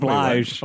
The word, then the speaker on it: Blige: Blige.